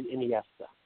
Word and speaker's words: Iniesta: Iniesta? – Andre Iniesta.